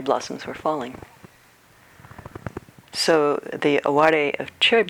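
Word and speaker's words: aware (あわれ): In Japanese, the word aware. It's spelled like aware, A-W-A-R-E, but it's aware.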